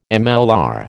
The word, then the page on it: MLR: American English